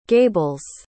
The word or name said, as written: gables